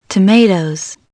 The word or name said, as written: tomatoes